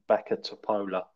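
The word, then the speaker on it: Bačka Topola: Boo Boo Topless. – I think it's pronounced Baka Topola.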